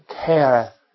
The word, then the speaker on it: kareth: Kareth means kareth. This is a long A.